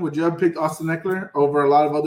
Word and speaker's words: Ekeler: Ekeler. – What? – That's what I'm going with. – So we got Austin Ekeler. – Ekeler. Like, you even mess it up. Ekeler.